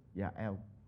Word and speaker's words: Yael: And that's how you pronounce it. Yael.